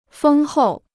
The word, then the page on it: 丰厚: fēng hòu